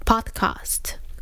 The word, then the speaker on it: podcast: 那所以我也没有去注意说，诶，这样子其实这种美国来的东西，这个是一个外来语哦，所以应该要尊重原创者的发音哦，应该叫做 iPod，叫做 podcast。